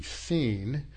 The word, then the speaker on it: Sin: Okay, if you were to, that's how most of us pronounce it, as it's anglicized, but it would be, in Hebrew, it would be seen, and it is the root of Sinai, okay?